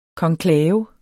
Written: konklave

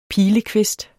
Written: PILEKVIST

